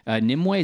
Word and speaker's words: Nimue: Nimue, is that how you pronounce her name? – Yeah.